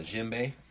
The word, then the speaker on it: djembe: It's a djembe. – Okay. – The D is silent.